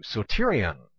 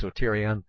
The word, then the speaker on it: soterion: The Greek word for salvation is number 4992, that's G4992, soterion is what it is, that's how it's pronounced, soterion, or at least close to that.